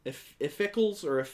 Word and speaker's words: Iphicles: Iphicles. – Heracles. And they were both kept in the same room. – Okay. – Iphicles. – It rhymes with sickly, so I'm okay with that.